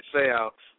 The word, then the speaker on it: Seau: Okay, Durson, Ray Easterling, Junior Seau, or is that how you pronounce it? – Junior Seau.